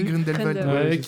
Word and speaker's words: Grindelwald: J'ai jamais su le prononcer. – Oui, Grindelwald.